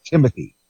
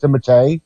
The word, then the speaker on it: Timothée: I know it's not Timothy. It's pronounced Timothée. – Okay. – Or something, because the French pronunciation is Timothée.